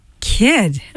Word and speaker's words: Kidd: Kid. All right. K-I-D-D. You don't pronounce the E. Kid.